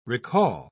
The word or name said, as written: recall